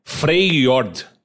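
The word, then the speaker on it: Freljord: Fjordir? Sei lá o nome. – Fjord. – Como que é? – Freyjord. – Ah, mudou um pouco a pronúncia, Mudou um pouquinho, Tem a Iloa, Ilawa? – Ilaui.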